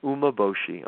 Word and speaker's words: umeboshi: So the first ingredient is it's called umeboshi. I'm going to spell it. It's U-M-E-B-O-S-H-I, umeboshi.